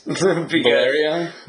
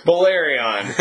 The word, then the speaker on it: Balerion: Balerion? – Balerion.